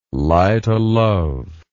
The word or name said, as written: light-o'-love